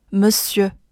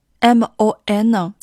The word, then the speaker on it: Monsieur: 首先，第一个 Monsieur M O N 在这里不读 mon，读一个 m m，后面的 s 是最关键的地方，最后的尾音 e 是一个要把嘴往出凸的 e 的音。